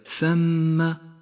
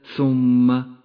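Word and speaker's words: ثَمَّ: فالثاء مفتوحة وليست مضمومة فهي ثم وليست ثم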